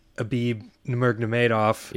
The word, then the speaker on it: Khabib Nurmagomedov: Well, and apparently Dana White is saying that he's losing his title before UFC 223, and the winner of our boy Khabib Nurmagomedov.